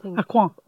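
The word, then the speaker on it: Aucoin: Am I pronouncing it right? Kevin Acorn? – Acorn.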